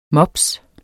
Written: mops

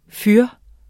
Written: fyr

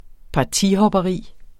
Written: partihopperi

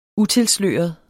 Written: utilsløret

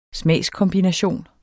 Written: smagskombination

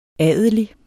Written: adelig

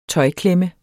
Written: tøjklemme

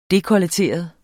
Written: DEKOLLETERET

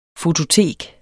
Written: fototek

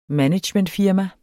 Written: managementfirma

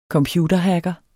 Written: computerhacker

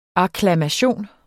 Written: akklamation

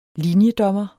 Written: linjedommer

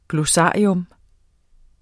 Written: GLOSSARIET

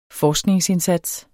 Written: forskningsindsats

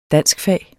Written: danskfag

